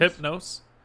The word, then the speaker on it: Hypnos: How do you say this? Hypnos? – Hypnos. Hypnos. – I don't know why the N in that is throwing me off. And sorry, not the N, the H.